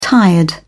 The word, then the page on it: tired: British English